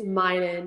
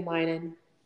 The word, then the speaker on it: Meinen: Haley, I can't really pronounce your last name, so I don't want to mess it up. So I'll let you know, say your name. – It's Meinen. It's Haley Meinen.